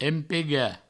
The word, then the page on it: empegar: empega